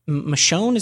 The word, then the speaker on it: Michonne: And there's still spinoffs of that, like the Michonne, is that how you say it? – Yeah.